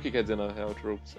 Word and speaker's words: tropes: Mas se pronuncia Tropes, então. – É tropes, é.